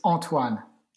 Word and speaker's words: Antoine: If you want to say it correctly, it's Antoine.